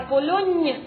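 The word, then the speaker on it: Pologne: Она напоминает вам, что там читается мягкое «ни». Помните в этом буквосочетании? «Л-М» – «Литали», «Ля Полонне» и «Лирлянда».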